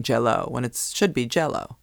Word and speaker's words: Jell-O: my Uncle Bud and Aunt Helen always brought the salad to family gatherings at Lake Harriet, and Aunt Helen's specialty was Jell-O. Emphasis on gel.